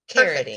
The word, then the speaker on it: Carody: So this person's name is Carity. – Oh. – They said, also, my name is pronounced like parody, but with a C. Carity. – Perfect. – Yes, and what a cute name, Carity.